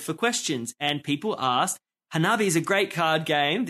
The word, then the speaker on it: Hanabi: I think that's how you say it. Hanabi. – Oh, it's Hanabi. Hanabi.